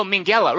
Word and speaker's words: Minghella: Oh, Minghella.